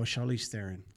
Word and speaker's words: Charlize Theron: And that, oh, Charlize Theron. – Yeah. – She corrected everybody. I said, everybody's been pronouncing it wrong for years.